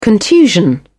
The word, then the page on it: contusion: British English